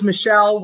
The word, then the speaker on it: Michele: It's Michelle with a 1L.